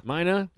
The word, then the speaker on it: Mina: Did I say that right? – Mina.